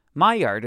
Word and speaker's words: Maillard: Maillard is pronounced roughly as my yard.